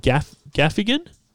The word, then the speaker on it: Gaffigan: Jimmy Gaffling. Well, what's his last name? The most famous comedian in the world. I can't even pronounce his last name. Jim Gaffigan? Yeah, that sounds right.